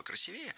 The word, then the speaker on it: красивее: И вообще, она была в претензии к художникам, потому что, наверное, хотела, чтобы она вышла красивее. Она 58-го года рождения, а всем говорила, что 67-го. – Красивее, неверно, правильно, красивее. В этом слове сохраняется ударение исходного прилагательного. Красивый, красивее.